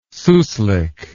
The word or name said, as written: souslik